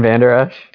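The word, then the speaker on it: Vander Esch: Leighton Vander Oosh.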